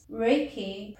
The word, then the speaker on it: Reiki: Reiki, pronounced Rei like the sun's rays and Ki like the key of the door, is a Japanese word for universal life force energy and describes a system of natural hands-on healing.